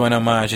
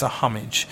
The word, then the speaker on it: homage: It can't just be an homage because you want to do an homage. It has to be, and why am I pronouncing it that way? God damn it, it's a homage.